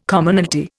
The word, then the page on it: Commonalty: Indian